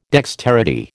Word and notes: dexterity: American English

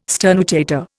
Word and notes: sternutator: Indian

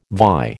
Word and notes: vie: American English

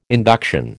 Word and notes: induction: American English

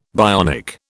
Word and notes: bionic: British English

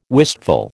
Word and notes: wistful: American English

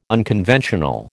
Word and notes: unconventional: American English

